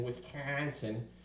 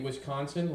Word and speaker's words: Wisconsin: The last year and this year, it's in Wisconsin. You got to say it right, because if you say Wisconsin like normal people do, they'll correct you and say, no, it's Wisconsin. You got to get that in your back of your throat to say it right.